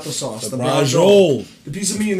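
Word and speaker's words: braciole: It's not prosciutini, but it's brajol. There you go.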